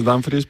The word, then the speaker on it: Dumfries: Sí, sí, porque aquí en principio decíamos Dumfries, pero estamos escuchando a los compañeros que dicen Dumfries.